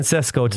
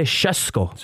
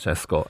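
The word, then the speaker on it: Šeško: I was saying Sesko today, mm-hmm. and Manchester United fan Dave Moore. He was correcting me, saying, no, you have to say Sesko. – Sesko.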